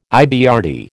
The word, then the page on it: IBRD: American English